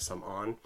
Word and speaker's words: Samorn: In the book, it's Samorn. So, like Samorn, but it's actually pronounced in Khmer Samon.